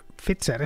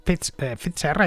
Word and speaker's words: Pfizer: No, a ver, Pfizer es un apellido alemán y como tal pues tenemos que al menos aproximar la pronunciación al original. Antonio Banderas, por ejemplo, vive en Estados Unidos, pero nadie dice Antonio Banderas, decimos Antonio Banderas, pues lo mismo. Pfizer hay que pronunciarlo así porque una P, una F y una Z, pues no exactamente como lo haría un tipo de Renania, pero sí al menos pronunciarlo como se debe pronunciar en alemán.